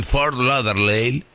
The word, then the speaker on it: Fort Lauderdale: Esteban, seguí en Fort Lauderdale, lo dije bien, y mañana Sí.